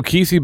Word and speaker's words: KSI: Um, no, the other guy, Kesey. – I think it's it's it's it's uh, KSI. That's how you say his name.